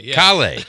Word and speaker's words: Cale: It looks exactly like kale, so that's how everyone says it, but it's Cale.